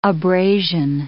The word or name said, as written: abrasion